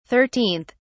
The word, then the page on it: 13th: British English